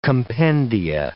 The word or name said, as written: compendia